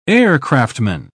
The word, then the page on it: aircraftman: British English